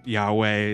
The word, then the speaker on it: Yahweh: And it's so cool because like, it's like, I guess the closest would be YHWH. – Okay. – And you hear Yahweh is one potential pronunciation, but you have like the four letters or four syllable word.